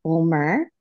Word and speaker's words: Bulmer: Today, we are so excited to announce we have a special guest, Katie Bulmer.